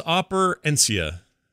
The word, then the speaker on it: Operencia: Brian, Sorry, Operencia or Opercernic? I don't know how you say it. – I was going to go with Opercenia. Opadopoulos. – It's Operencia. That's what we'll go with.